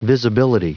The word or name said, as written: visibility